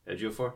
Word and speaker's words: Ejiofor: Ejiofor? – Yeah, there you go.